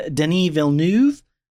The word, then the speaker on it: Denis Villeneuve: Director is Denis Villeneuve.